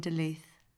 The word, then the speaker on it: Duluth: What was it like growing up in Duluth?